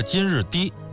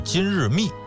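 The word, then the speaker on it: 金日磾: 在这儿啊，我们稍微再多说一句啊，就是我看有的纪录片里边呢把这个金日低读成是金日密，我查了相关的这个资料没找到这个密字的发音的出处，所以咱们还是说成是金日低吧。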